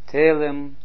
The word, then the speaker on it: Telem: Telem. Ziet u, als zo tweemaal e, dan wordt het verspringt het de klemtoon naar de eerste letter geeft. Geeft niet.